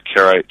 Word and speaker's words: Karaite: Yeah, Karaite Jew.